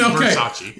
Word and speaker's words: Versace: It's Versace. I can't afford it, so I don't know how to pronounce it. – With a fucking V. – Okay. Versace.